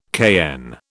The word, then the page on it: cayenne: British English